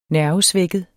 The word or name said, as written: nervesvækket